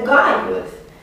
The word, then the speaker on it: Caius: Care așa se pronunță. Caius. Noi știm deja, cred că v-am convins că este Gaius. Că este doar o grafie de dinainte de Carpius. Lunga cu G, care se pronunță de fapt G.